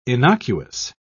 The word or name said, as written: innocuous